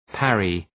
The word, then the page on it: parry: {‘pærı}